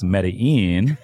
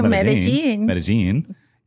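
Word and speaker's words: Medellín: I was in Medellin, Colombia, and some of the listeners might be thinking, oh, it's pronounced Medellin. It's Medellin.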